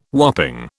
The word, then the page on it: whopping: British English